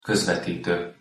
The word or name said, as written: kozvetito